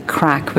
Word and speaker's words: craic: Well, you are, and I think that's where, and as we call it the crack, which is the Gaelic word for fun, and it's spelled C-R-A-I-C.